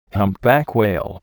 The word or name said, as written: humpback whale